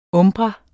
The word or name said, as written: umbra